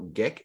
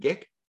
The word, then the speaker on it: Ghik: So today my guest is Virgil Ghik. Is that how you pronounce it? Ghik? – That's perfect.